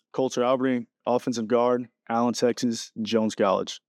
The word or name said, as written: Colter Alberding